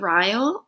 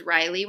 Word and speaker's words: Ryle: And is that how you say his name? – Yeah, I thought it was Riley when I was actually reading it. – No. – No.